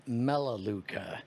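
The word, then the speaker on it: Melaleuca: And he goes, are you familiar with Malaluca? – No. – And so I tell him, funny enough, I am familiar with Malaluca. – That's I've always heard it pronounced Melaluca,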